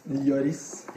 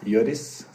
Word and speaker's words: Lloris: Eh, Hugo Lori. Vår eh, Loris. Lloris. Lloris. Spansk etternavn. Spansk etternavn. Ja. Jeg som trodde at eh, man ikke uttalte S på franske spillere.